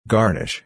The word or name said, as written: garnish